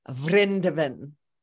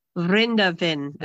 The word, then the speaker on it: Vrindavan: So that's how it's pronounced. I knew I was pronouncing it wrong. Can you say it again? – Vrindavan. – Vrindavan. Okay, we've been calling it Vrindavan because we don't know Sanskrit.